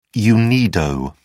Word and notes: unido: British English

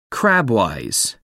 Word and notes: crabwise: American English

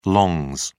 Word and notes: longs: British English

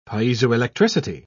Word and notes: piezoelectricity: British English

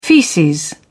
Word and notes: feces: British English

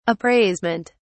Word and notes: appraisement: British English

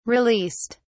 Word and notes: released: British English

